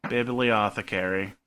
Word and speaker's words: bibliothecary: Bibliothecary. – Okay. – Bibliothecary. It's apothecary and biblio.